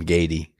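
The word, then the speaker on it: Gaede: A lot easier than trying to pronounce my last name, Gaty. – Gaty. And it's G-A-E-D-E? – Yes.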